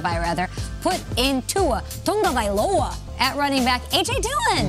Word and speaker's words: Tagovailoa: Wide receiver is really bleak, but we start with a quarterback, Tua Tagovailoa. Great matchup on Sunday. How did I say this yesterday? – Tua Tagovailoa.